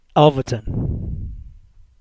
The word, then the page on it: Alverton: English